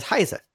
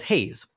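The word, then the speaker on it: Heize: I pronounced the name of that singer as Heise. And yet, when watching the Mnet Music Awards, they were pronouncing her name as Haze. This bothers me. – Oh. – Yes, that's the way they pronounce it. They are technically correct in this situation. But they have also specifically said they were inspired by the German word, Heise.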